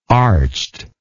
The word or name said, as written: arched